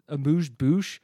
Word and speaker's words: amuse-bouche: But yeah, let's go to the first course, which I thought this term, amuse-bouche, I thought. – Say it again. – Amuse bouche. Isn't that how you say it? – Dude, I don't know. – Yeah, you got it.